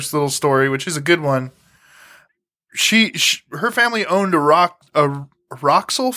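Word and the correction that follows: Rexall: pharmacy. Did I say that right? – Rexel. – Like Rexel.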